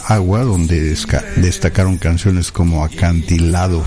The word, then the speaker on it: AUA: Vamos a pasar ahora con este grupo que se llama Agua, bueno, A-U-A, pero se pronuncia Agua.